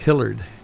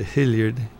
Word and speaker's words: Hilliard: And on the sign, it looks like Hillard, but it's actually Hilliard.